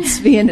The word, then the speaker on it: Svien: Svien is more.